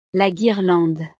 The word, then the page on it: la guirlande: French